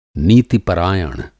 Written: नीति-परायण

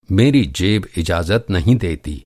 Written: इजाज़त

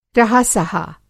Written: रहा-सहा

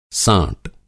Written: साँट

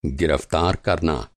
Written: गिरफ़्तार

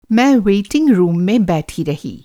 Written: वेटिंग रूम

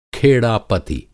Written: खेड़ापति

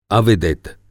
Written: अविदित

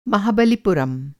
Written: महाबलिपुरम

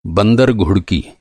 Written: घुड़की

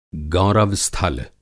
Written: गौरव-स्थल

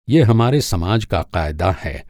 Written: क़ायदा

